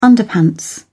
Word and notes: underpants: British English

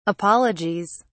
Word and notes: apologies: British English

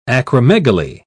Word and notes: acromegaly: British English

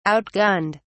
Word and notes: outgunned: British English

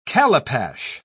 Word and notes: calipash: British English

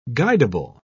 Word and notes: guidable: British English

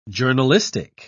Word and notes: journalistic: British English

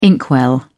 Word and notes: inkwell: British English

